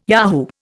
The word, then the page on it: yahoo: Indian